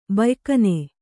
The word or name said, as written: ಬಯ್ಕನೆ